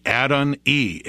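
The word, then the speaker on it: Adoni: The yod on the end is pronounced E, Adon-E, and it makes it a possessive form.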